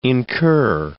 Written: incur

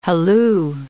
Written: halloo

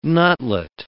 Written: nutlet

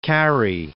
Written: Carey

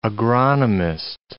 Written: agronomist

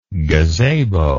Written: gazabo

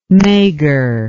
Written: maigre

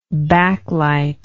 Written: backlite

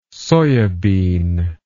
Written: soyabean

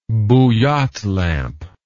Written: bouillotte lamp

